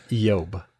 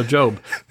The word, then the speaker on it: Job: And by the way, it's probably correctly pronounced Yob. – Oh, yeah. Yob. Yeah, they didn't have a hard J. – They didn't have the hard J. But we'll go with Job. So, Job, this is going to be a great thing.